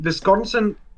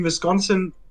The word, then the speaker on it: Wisconsin: Er ist von den Green Bay Packers, also einer, einem in Wisconsin, sorry, Wisconsin, das ist immer noch falsch gesagt. Ihr wisst, was ich meine. – Wisconsin.